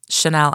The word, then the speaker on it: Chanel: Chanel, I replied, C-H-A-N-E-L.